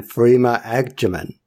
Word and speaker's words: Freema Agyeman: You got to meet some of our idols in the sense of David Tennant, Catherine Tate, and Freema Agyeman.